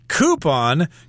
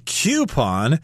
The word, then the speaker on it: coupon: Coupon could also be pronounced coupon. They're both correct.